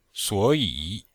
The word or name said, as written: suo3yi3